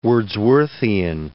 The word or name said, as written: Wordsworthian